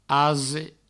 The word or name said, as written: ase